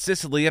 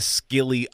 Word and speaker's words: Scilly: Isles of S-C-I. Yeah, Isles of Scilly, S-C-I-L-L-Y. I saw that and I thought Sicily and I thought Italy. Well, maybe not quite. They're over by Cornwall, England, basically. I think it might be pronounced Scilly, and it's a little bit silly.